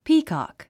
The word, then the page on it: peacock: píːkὰk; ピーコック